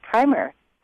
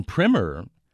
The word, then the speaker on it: primer: On primer, it's true. In American English, we say primer for a little book like Strunk and White, The Elements of Style, is a primer.